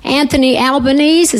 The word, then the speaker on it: Anthony Albanese: I wanted to also especially thank Anthony Albanese.